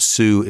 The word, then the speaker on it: Sioux: The Sous Chef, Sioux is spelled S-I-O-U-X, as in the Sioux Nation.